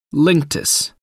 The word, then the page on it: linctus: American English